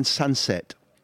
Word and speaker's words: Sancet: is a kid who's called Ian Sunset, and that's pronounced the best I can manage. So for anybody who doesn't know about this kid, Sunset is spelled S-A-N-C-E-T, and Ian is a Basque name.